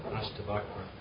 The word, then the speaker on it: Ashtavakra: Ashtavakra.